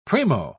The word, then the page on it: primo: British English